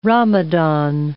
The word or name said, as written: Ramadan